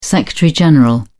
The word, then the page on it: secretary general: British English